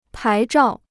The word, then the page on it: 牌照: pái zhào